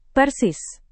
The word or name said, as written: persis